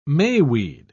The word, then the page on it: mayweed: British English